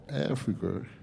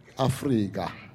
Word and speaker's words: Afrika: So, just for your information, when you spell his name, it's got, we don't spell it with a C, we put a K. And we don't pronounce it Africa or Africa. We pronounce it Afrika.